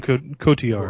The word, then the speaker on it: Cotillard: Marion Cotillard.